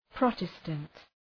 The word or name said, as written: Protestant